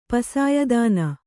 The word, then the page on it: ಪಸಾಯದಾನ: Kannada